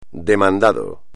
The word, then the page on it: demandado: espagnol